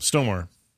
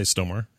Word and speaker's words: Stormare: How do you say that guy's name? – Stomar. – Stomar? – I'd say Stomar, yeah.